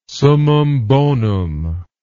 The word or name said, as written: summum bonum